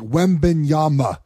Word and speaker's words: Wembanyama: like Wembanyama.